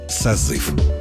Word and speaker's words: созыв: Еще несколько десятилетий назад ударение в слове «созыв» считалось вариативным. То есть ударным мог быть как второй слог, так и первый. В середине 20 века норма изменилась. Правильным признан единственный вариант произношения – «созыв».